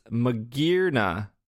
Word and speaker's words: Magearna: I don't have that name in front of me, but I believe it's Magearna.